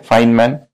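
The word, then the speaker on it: Feynman: तो ये टेक्निक है, इसको कहते हैं फाइनमैन टेक्निक, ठीक है। फाइन, ये इसका प्रोनाउंसिएशन है FAYN है, पर प्रोनाउंसिएशन है फाइन, ये MAN मैन, फाइनमैन टेक्निक।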